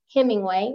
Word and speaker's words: Hemingway: My name is Nakita, spelled with an A, Hemingway with one M.